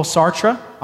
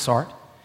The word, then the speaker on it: Sartre: This is the view of Jean-Paul Sartre, often mispronounced Sartre.